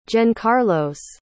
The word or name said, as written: Jencarlos